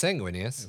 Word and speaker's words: Sanguinius: Why do you pronounce it weird? – Sanguinius? – Yeah. – It's Sanguinius. – Sanguinius, not Sanguinius.